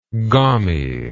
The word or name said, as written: gommie